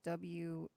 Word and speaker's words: Worcester: And all of a sudden she's like giving me, "No, you don't say it like that. You forget the R altogether." – There's it's almost like you're saying like Wooster, kind of like Worcester.